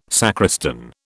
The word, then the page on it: sacristan: British English